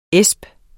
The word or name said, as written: asp